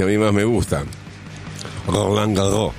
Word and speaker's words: Roland Garros: Roland Garros, si no sabes pronunciar, lo lamento, yo sé pronunciar bien. Esto se pronuncia. ¿No es cierto, Mariano? – ¿Cómo va, equipo? ¿Todo bien por ahí? Así, está muy bien pronunciado.